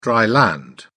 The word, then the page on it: dry-land: British English